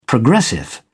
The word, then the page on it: progressive: British English